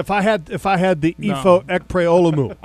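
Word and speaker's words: Ekpre-Olomu: Ekpre Olomu. – Ekpre Olomu.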